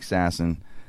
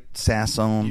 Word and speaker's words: Sasson: Am I saying your name right? Sasson. – You got it Sasson. – Right. – Sasson. Sasson. I'm to make sure you got it right, Mikey. – S-A-S-S-O-N, so Sasson.